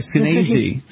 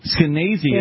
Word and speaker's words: Skenazy: No, it rhymes with crazy. – So sorry, Skenazy. Skenazy is with us.